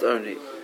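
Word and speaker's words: oni (עני): There's no vav in the word. It's pronounced oni, but oni ksib. It's written as lachem oni.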